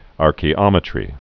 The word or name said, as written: archaeometry